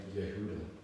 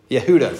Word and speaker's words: Yehuda: Grant's gonna like this because it rhymes with Yoda, but the man who resurrected it was Eliezer ben Yehuda. – Yehuda, thank you. Ben Yehuda. – Sounds like Yoda.